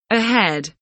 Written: ahead

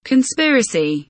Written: conspiracy

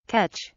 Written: catch

